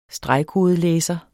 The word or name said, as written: stregkodelæser